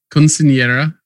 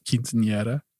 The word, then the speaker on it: quinceañera: Matt steps in, knocks on Carlota's door, and she is getting ready for a quinceañera. I probably said that incorrectly. – Yep, quinceañera.